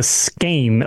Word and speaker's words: skein: It's a skein, or skein. It's like a skein, like a skein of yarn, apparently. – Right. Yeah. So like skein beetles and scorpions and spiders, S-K-E-I-N.